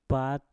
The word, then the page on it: pat: Mpur